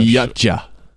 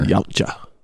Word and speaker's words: Yautja: Yutja. Is that how you're supposed to pronounce it? That's what the predator species is. Y-A-U-T-J-A. – Yeah, I read that somewhere. – Yutja. – Yeah. – Yep. Unless it's a soft J. Yautja. I don't think so.